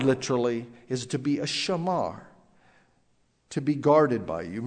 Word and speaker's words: shamar: Keep is such a seemingly innocent word, but keep in the Hebrew is shamar. We would English spell it S-H-A-M-A-R, shamar.